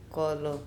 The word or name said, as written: kOlo